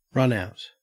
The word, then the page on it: runout: Australian English